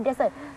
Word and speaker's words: dessert: แต่เราต้องมีความกล้าแสดงซึ่งหนูอยู่ในชั้นนั้นเลยลูกนะคะหนูเป็นคนเฟรนลี่นะทัวริสชอบมากเพราะหนูมีเนี่ยมีการใช้ acting นะท่าทางประกอบทุกอย่างลูกแต่เป็นสิ่งที่ดีจำได้ไหมขนมหวานเนี่ยหนูพูดคำว่า dessert ซึ่ง dessert นี่นะคะหนูต้องเข้าใจว่า dessert คุณค่ะมาค่ะชมค่ะอยากทานทะเลทรายไหมคะซึ่งได้ไหมลูกไม่ได้ค่ะเรามาดูกันนะลูกนะขนมหวานเนี่ยอ่านว่า dessert เพื่อตามหรือ dessert dessert สะกดว่า d-e-s-s-e-r-t dessert แต่ถ้า dessert ค่ะ dessert วิ dessert ไปแล้วตายอ่ะไม่อยากไปเลยอ่ะก็อ่านว่า dessert นะ dead แปลว่าตายเดตายอุ๊ยมี s 1ตัวค่ะ